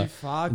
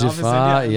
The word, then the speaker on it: Giffard: Aber Giffard, genau. Giffard, ja, ja Französisch, genau.